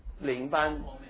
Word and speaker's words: 领班: 那 you know, 灵那个灵班，你们知道灵班这个字哈？灵班 the foreman, 这个台湾那个领班，我的发音不准啊，领袖的领啊，领班 okay, you know, Who is the foreman?